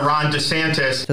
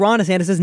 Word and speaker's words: DeSantis: Hi, this is Governor Ron DeSantis.